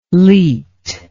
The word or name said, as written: leet